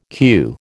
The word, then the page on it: queue: American English